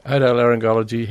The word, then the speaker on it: otolaryngology: Otolaryngology, head and neck surgeon. – Otolaryngology.